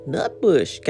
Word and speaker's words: Nutbush: Nutbush. Got to say it like that if you saw the movie.